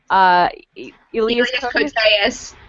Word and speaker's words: Elias Koteas: Like, I was too young to understand what was happening, but I was having a hardcore crush on Elias Koteas I don't know how you say his name. – Elias Koteas. – There you go.